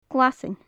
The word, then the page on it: glaisean: gaelic